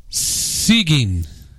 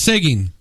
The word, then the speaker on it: Seguin: Stephanie said it's pronounced Seguin. – Of course it is. – Seguin.